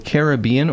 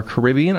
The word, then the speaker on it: Caribbean: Caribbean or Caribbean. – I feel like I should know that. – I know that I alternate between the two, personally. – Yeah. – I never stick to just one pronunciation.